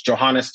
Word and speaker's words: Johannes: It's Johannes. You spelled like Johannesburg in South Africa.